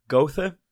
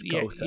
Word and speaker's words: Goethe: So that comes from and I'm going to say this incorrectly, because I always do, Goethe. – Well, it's Goethe.